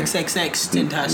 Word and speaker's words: XXXTentacion: who made a joke about XXXTentacion. – You missed the X in there. – XXXTentacion. – There you go. Don't miss the X.